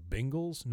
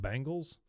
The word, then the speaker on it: Bengals: learn how to say the word Bengals, not Bangles.